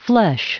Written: flesh

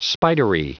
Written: spidery

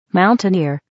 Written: mountaineer

